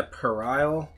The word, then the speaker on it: puerile: Purell? – In a puerile stoner comedy. – Let's let the audience at home pronounce this P-U-E-R-I-L-E, stoner comedy to be part of God's master plan.